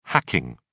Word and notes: hacking: British English

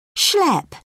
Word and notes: schlep: British English

